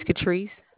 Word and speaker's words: Catrice: My name is Catrice. – Spell that for me, please. – C-A-T-R-I-C-E.